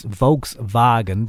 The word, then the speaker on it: Volkswagen: It's Volkswagen because that's how they pronounce those letters.